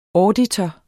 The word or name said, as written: auditor